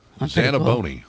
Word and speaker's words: Zanaboni: Zanaboni. – Zanaboni. – Spell it. – Z-A-N-A-B-O-N-I.